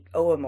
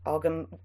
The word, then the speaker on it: Ogham: Yeah, well, as we said when we did our special on When is a Tree Not a Tree, looking at the Oum or Ogham, you can pronounce it either way,